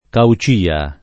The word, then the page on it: Caucia: lingua italiana